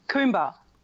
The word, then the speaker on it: Coomber: Coomber.